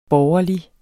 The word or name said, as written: borgerlig